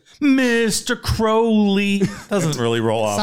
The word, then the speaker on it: Crowley: There's no audio of him pronouncing his name, but he, in some of his poetry, he rhymed his name with unholy, right? – Unholy Crowley.